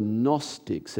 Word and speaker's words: Gnostics: And then while he's trying to figure out how do I respond to this, there was another group of guys called the Gnostics. It's a silent G at the start of that.